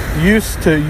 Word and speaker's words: used to: Used to, use without d, but it sounds exactly the same. We used to, did you used to? We can't pronounce d next to t.